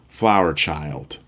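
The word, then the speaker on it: FLWR CHYLD: And because he's a newer artist, his name is spelled FLWR, then CHYLD, two separate words, Flower Child.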